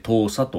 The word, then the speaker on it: 東沙: 取演習を明言というニュースであります、えー、東沙諸島はあの西沙とか南沙とかね、えー、パラセルスプラトリー諸島のお持ちのことですが、えー、の東を書きます東に、えー、山水に少ないと書いて東沙と読む